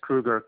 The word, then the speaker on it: Kruger: Last name is Kruger, K-R-U-G-E-R. – Matt? – First name is Jan, J-A-N.